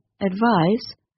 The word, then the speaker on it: advise: The noun form of the word advice is written with the letter C, which is said like but the verb advise is written with the letter S, which is pronounced like z.